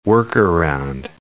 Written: work-around

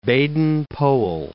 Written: Baden-Powell

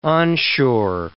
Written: onshore

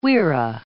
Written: wirra

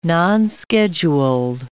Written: nonscheduled

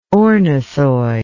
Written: ornithoid

